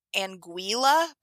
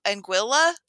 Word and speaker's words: Anguilla: So like, I always thought it was Anguilla, but I think it's actually pronounced Anguilla, like the, it's not like a Y sound.